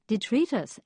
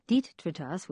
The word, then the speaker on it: detritus: Finally, a couple of corrections, one from Judy Dernberg, who writes, "Robin, Robin, Robin, it's detritus, accent on the middle penultimate syllable, not detritus, which I said, reverting back to my 10-year-old inner self."